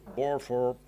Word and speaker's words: Burford: Así que es otra buena noticia en este juicio histórico y multimillonario que ha entablado el fondo, Burford o Barford, como me diga que se pronuncia. – Burford.